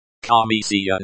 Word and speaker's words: Комиссия: Комиссия note. The stress is on the second syllable.